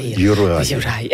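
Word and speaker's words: Juraj: Personnellement, moi, j'ai de la difficulté avec Yurav Slavkovski. – Yurai.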